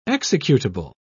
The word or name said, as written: executable